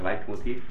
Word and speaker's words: Leitmotiv: La parola è tedesca, infatti è la pronuncia Leitmotiv, questa parola tedesca.